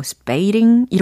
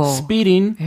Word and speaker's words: speeding: 특히 제니 워든 스피링이라고 발음이 안 되고 스페이링 이렇게 들렸어요. 아, 스페이링. – 네. 오, 생소해요. – 그렇죠. – 스피링.